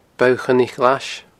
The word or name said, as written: Bogha Choinnich Ghlais